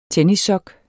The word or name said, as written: tennissok